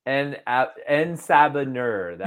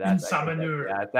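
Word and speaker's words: En Sabah Nur: how do you pronounce his name? Let me just translate that for you. Apocalypse. – Oh, okay. Yes. – En-Sabanur. – En-Sabanur. – There you go.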